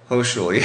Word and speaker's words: Hochschule: He's presented masterclasses at Austria's Music Hochschule. Am I saying it right? – Yes, you are. – Hochschule, yeah.